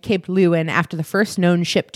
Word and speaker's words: Leeuwin: you get Cape Leeuwin. That's spelled L-E-E-U-W-I-N. Cape Leeuwin.